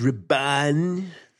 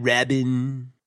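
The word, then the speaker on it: Rabban: It's Raban. – No, sorry. – Who's playing? Thank you. One of the Dune super fanboy nerds. Go ahead. – It's Raban, not Rabin.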